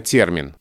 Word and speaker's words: термин: А если ты скажешь термин, хотя нормативно термин, то, я думаю, заметит меньшее количество людей.